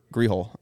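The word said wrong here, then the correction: Ghiroli: The Baltimore. – I thought she used to cover the Nationals. – Britt Giroli, you mean? – Oh, yeah.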